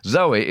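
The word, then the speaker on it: Zowie: According to Nicholas Pegg's A Complete David Bowie, Zoe is pronounced like the girl's name Zoe, intended as a masculine version of the Greek word for life.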